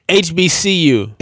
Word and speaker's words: HBCU: And that's what you call an HBU. Did I say that right? – HBCU.